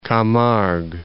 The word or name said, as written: Camargue